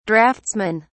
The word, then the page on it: draughtsmen: British English